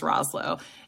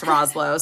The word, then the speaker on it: Rosselot: I used to wear like a Coach Roz shirt, and because they call him Coach Roz because our last name's Rosselot. It's a thing because no one can pronounce Rosselot. So they're like Rosselot. And I'm like, sure, whatever.